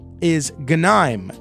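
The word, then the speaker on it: Ganim: And then he says, P.S., the pronunciation for the crooked mayor of Bridgeport is Ganeim, as in can I'm.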